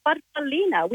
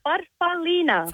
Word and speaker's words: farfallina: Well, growing up, as we're little girls, Mum called it a farfallina, which in Italian means a little butterfly. – Oh. – How do you say it? – Farfallina.